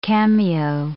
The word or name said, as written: cameo